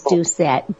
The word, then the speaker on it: Doucette: It's spelled C-E-C-E. And my last name is Doucette, D-O-U-C-E-T-T-E.